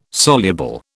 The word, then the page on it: soluble: British English